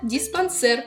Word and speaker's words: диспансер: Самый умный в мире сэр, посещая диспансер.